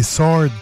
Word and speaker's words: sword: Je dis sword, mais c'est pas ça, c'est sword qu'il faut dire. – Sword. sword. Faut pas prononcer le W.